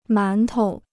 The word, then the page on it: 馒头: mán tou